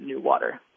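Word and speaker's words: NEWater: Right, they've kind of smushed together and created a portmanteau phrase where it's as they type it out, it's capital N-E-W and then lowercase A-T-E-R, but they pronounce it new water.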